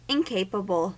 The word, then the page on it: incapable: American English